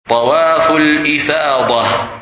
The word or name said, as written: Tawaf Al-lfadah